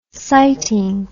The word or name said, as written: siting